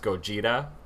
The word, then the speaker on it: Gojira: In the like OG movie, you see it spelled and pronounced Gojira with like a J sound.